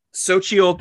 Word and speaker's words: Xochitl: Speaking of which, I absolutely loved America Chavez, Xochitl Gomez, which I looked up, that's the way to pronounce her name.